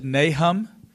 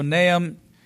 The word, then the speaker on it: Nahum: Some people pronounce it Nahum. Either one is correct. It's kind of like the word humble. Some people say humble, others say humble. Same word. So Nahum chapter 3.